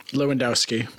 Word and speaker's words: Lewandowski: Lewandowski.